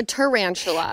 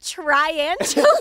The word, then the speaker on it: tarantula: Or a tarantula. – It's tarantula, not triantula.